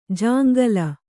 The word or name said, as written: ಜಾಂಗಲ